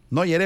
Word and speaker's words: Neuer: O sea, es que le hubieras visto la cara a Neuer. Neuer, Epa, así es el apellido, así se pronuncia.